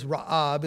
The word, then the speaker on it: Ra'ab: The Hebrew word is Ra'ab, it's spelled R A A B.